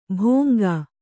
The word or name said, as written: ಭೋಂಗಾ